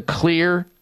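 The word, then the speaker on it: Xlear: Well, there is a way that you can help clear it out with a company by the name of Clear, but Clear is spelled with an X. X-L-E-A-R, the X in their name stands for xylitol, one of the important components in the Clear Max Nasal Spray.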